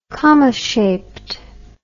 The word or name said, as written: comma-shaped